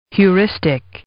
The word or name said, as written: Heuristic